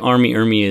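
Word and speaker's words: R. Lee Ermey: I think Arlie Ermey is... Is that how you say his name? Yeah.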